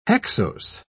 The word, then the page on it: hexose: British English